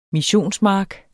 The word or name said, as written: missionsmark